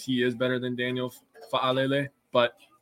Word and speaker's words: Fa'alele: Number four, I have Daniel Fa'alele.